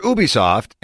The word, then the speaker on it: Ubisoft: As for Ubisoft, and yes, that's the official pronunciation, Ghost Recon Breakpoint, Rainbow Six Siege, and Tom Clancy's The Division are all on tap.